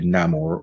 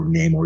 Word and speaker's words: Namor: The movie introduces two new major characters, Riri Williams and Namor, or Namor, depending how you say his name. There's no right or wrong.